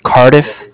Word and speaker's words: Cardiff: Yeah, my name is Brian with Cardiff. It's spelled C-A-R, D as in David, I-F-F as in Frank.